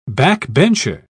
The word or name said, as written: backbencher